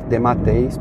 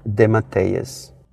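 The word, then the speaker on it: DeMatteis: E o último de, nome dele em português a gente leria, e eu leria, obviamente, Dematês, porque é como está escrito. É, mas na, quando a gente conversa né, com ele e tal, e escuta as pessoas falando a pronúncia do, do nome dele, ou como está sempre escrito no, nas revistas, é, JM, que seria JM, e a última palavra, o último nome dele é Demateias.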